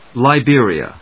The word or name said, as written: Liberia